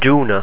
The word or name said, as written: dúnadh